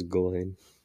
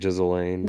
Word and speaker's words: Ghislaine: It is Ghislaine. Some people say like Ghislaine. – That's what I thought it was. I thought there was an S. – Ghislaine.